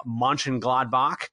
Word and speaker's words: Mönchengladbach: They're fifth in the Bundesliga, 3 points back of Borussia Mönchengladbach.